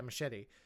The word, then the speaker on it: Muschietti: Yeah, Muschietti.